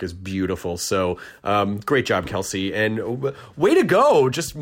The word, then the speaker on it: Qellessi: Instagram is it's pronounced Kelsey, but it's spelled... It's the at, and then Q-E-L-L-E-S-S-I, and Etsy is the same shop name.